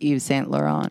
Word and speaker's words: Yves Saint Laurent: You know that's pronounced Yves Saint Laurent. – Eve? No, yeah.